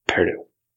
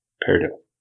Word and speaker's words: peridot: Peridot. – Our French professor, G.A., always called it peridot. But we're pronouncing it the French way, peridot, by drawing T. But he pronounced it the English way, peridot.